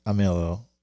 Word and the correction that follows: Armijo: Armello. I always forget how to pronounce his name. – Matt Armijo. – Yeah, Matt Armijo.